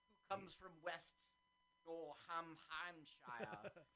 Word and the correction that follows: Worcestershire: I don't think that's how it's pronounced. – It's pronounced Worcestershire.